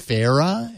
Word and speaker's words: Sphaera: I think it's pronounced Sphera, S-P-H-A-E-R-A.